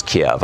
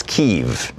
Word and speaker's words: Kyiv: Embassy in Kiev, or what used to be pronounced as Kiev. I guess it's Kyiv now.